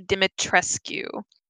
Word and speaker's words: Dimitrescu: We tried to figure out how to pronounce it. We're fairly certain it's Lady Dimitrescu.